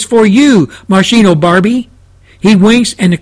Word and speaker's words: Moschino: M-O-S-C-H-I-N-O, Moschino Barbie.